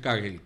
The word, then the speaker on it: Kagel: Por ejemplo, el caso de Kagel, en alemán, se pronuncia Kagel, que es Kagel, Mauricio Kagel.